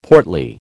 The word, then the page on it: portly: American English